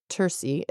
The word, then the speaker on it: terce: terce or terci. It's it's in Latin. In Latin, the way I learned it, this would be a hard C, so terce, 9 a.m.